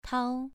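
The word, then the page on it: tao: tone 2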